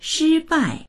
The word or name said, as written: shi1bai4